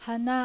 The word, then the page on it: Hana: Japanese